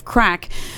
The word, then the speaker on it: craic: a bit of crack, which I was teaching people last week, remember C R A I C is the Irish word for fun, pronounced crack, not to be confused with the illegal substance.